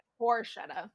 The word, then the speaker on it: Borchetta: scott bruschetta is that how you say his name bruschetta i feel like it bruschetta like i'm thinking like bruschetta, bruschetta. scott borchetta scott yeah. borchetta um, who was like you know her initial discovery.